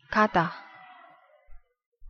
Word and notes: 가다: Korean

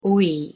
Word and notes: 오이: Korean

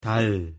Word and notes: 달: Korean

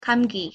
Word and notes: 감기: Korean

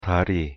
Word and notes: 다리: Korean

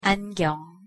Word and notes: 안경: Korean

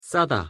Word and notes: 싸다: Korean

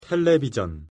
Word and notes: 텔레비전: Korean

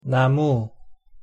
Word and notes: 나무: Korean

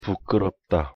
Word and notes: 부끄럽다: Korean

